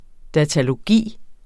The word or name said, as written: datalogi